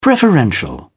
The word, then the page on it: preferential: British English